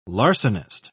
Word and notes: larcenist: British English

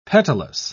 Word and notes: petalous: British English

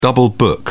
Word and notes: double book: British English

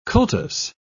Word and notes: cultus: British English

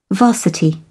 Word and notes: varsity: British English

